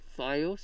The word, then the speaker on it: Fios: or Fios TV. I looked up the pronunciation on that because I wasn't sure. – So it's Fios? – It's Fios. – Fios. – Yeah. – Okay. – It's not FiOS.